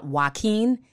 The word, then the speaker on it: Joaquin: They want her removed for the joke that she said about Joaquin.